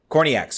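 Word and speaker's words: Kornieck: Ashley Hatch scoring in the 64th minute, and then Taylor Kornlick, I think I'm pronouncing that right. Korniak, sorry. I thought that was an L. Korniak, thank you.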